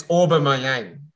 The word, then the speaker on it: Aubameyang: It's Aubameyang. Aubameyang.